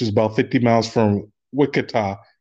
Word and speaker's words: Wichita: One, it's pronounced Wichita. – Two, oh, okay. Wichita.